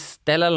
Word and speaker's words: stellar: With these stellar lineups. – Stellar. – I said that right. – R. There's an R. – You put an R at the end. – There's an R at the end.